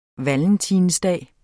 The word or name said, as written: valentinsdag